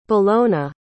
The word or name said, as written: bellona